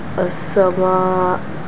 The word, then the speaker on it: السماء: What if you stop on the Hamza? How would you pronounce it? – As-sama. – Yes. Here you can make it longer though. And then the Hamza, when you have a sukun, you just cut.